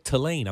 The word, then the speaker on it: Tulane: Yeah, Tulane.